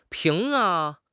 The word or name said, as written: ping2